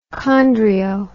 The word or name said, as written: chondrio